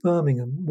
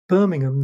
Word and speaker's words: Birmingham: And that is Birmingham, not Birmingham, as Americans might. – Right. – And if you want to say it properly, you have to say it like they do in Birmingham, where it's always raining, so everyone always has a cold. So people say Birmingham like that.